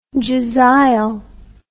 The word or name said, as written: jezail